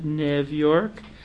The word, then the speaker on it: New York: In Hungary and in Austria, they called it Nev York.